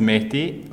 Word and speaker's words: Mehdi: Like, my full name is Mehdi. Like, it's spelled as M-E-H-D-I.